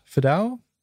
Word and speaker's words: Fidao: then it would be the proxy package for, from uh, Chris Fidao. That the right way yeah, to pronounce him, yeah.